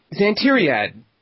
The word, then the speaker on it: Xantiriad: Next up from those scoundrels at the Ninja Fat Pigeons, Big Red Potion welcomes back the man whose name I always mispronounce, Xantiriad.